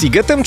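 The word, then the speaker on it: Sziget: Czy możemy ustalić, czy ten siget, mimo że jest przez SZ, to jednak jest sigetem czy sigetem? – Oczywiście, ponieważ w języku węgierskim szy czyta się jak sy, a aha, sy czyta się jak szy.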